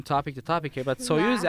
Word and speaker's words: Soyuz: Soyuz. Oh, Soyuz, like the Soyuz, like the spacecraft Soyuz.